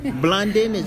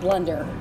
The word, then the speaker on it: Blunden: We get called Blunder often. – They don't see the E. – They don't. And so it's actually sometimes I will find myself saying when they can't figure out how to spell it, I'm like Blunder, but actually just with an N at the end instead of the R.